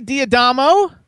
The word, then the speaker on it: D'Adamo: So we have Cindy Diadamo.